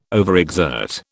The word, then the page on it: overexert: British English